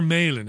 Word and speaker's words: Malin: Chris, is it Malin or Malin? How should I pronounce the surname? – It's actually Malin.